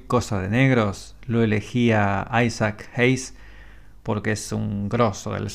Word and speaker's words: Isaac Hayes: La principal fue la de Barry White, pero también estaba Isaac Hayes o Isaac Ashes, se escribe así, pero es Isaac Hayes.